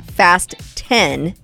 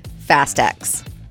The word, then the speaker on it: Fast X: So Fast X is pronounced Fast 10, not Fast X.